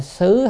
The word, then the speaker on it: xứ: chữ xứ hay là xử đó thì xứ là nơi chốn thì có nói vậy,